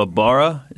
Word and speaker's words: Ibarra: So Ibarra, is that how you pronounce the city? – That's how I pronounce it. – Okay, that sounds good to me.